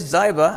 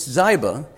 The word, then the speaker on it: Ziba: Now there was a servant of Saul's household named Ziba. It is Ziba. I looked at the self-pronouncing Bible. It's Ziba.